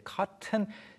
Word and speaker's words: cotton: 발음이 이제 우리가 그 면 또는 솜을 가리킬 때 cotton이라고 외래어처럼 표기하는 경우도 있는데 발음이 이제 cotton T 발음이 안 날 때가 많죠. 특히 미국 영어에서. – Yeah. So you can say cotton, but usually we say cotton. Cotton.